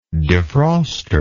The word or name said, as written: defroster